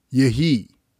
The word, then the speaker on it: Yehi: It is the word Yehi, Yod, He, Yod, pronounced Yehi.